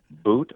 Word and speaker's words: Bout: But the Russians in Russia, if you talk to Russians, it's butt, like as in a rear end. So it's butt, boot, or now it's become bout. So it's tough to take a one-syllable last name and pronounce it in many different ways, but we've managed to do that in the States.